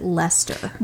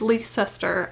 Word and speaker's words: Leicester: And some people pronounce it Lester. – You are missing a whole syllable. It is Leicester, and fight me.